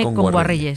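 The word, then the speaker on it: Kngwarreye: Emily Kame Nguayerre, lo he dicho mal. – Emily Kame. – Con Guarreye. – Emily Kame con Guarreye, sí.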